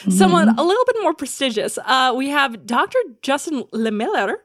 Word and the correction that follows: Lehmiller: Did I pronounce that? – Yep, close enough. Lay Miller. – Oh, Lay Miller.